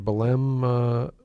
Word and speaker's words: Belém: I know your last article was Belém on Belém, Brazil. Is that right? Am I saying it right? – You're exactly saying it right.